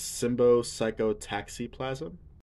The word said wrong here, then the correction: Symbiopsychotaxiplasm: Close. Symbio. Symbiopsychotaxiplasm. Yes.